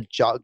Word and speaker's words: giallo: And I think these type of films are called giallo or giallo.